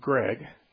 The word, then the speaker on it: Gregg: Zeitgeist, and put my name in there, Steve Gregg.